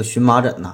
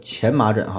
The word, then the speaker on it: 荨麻疹: 那说到这儿，我又想起个事儿，就比如咱们常说的这个荨麻疹呐，实际上呢，这个应该读作前麻疹哈，它是前这个音。但后来呢，现代汉语这个字典也是妥协了，加上了荨这个音。